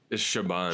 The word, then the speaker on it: Siobhan: Hermione Corfield playing Siobhan or Sagittai. I is terrible pronouncing that name, but that's... – It's Siobhan.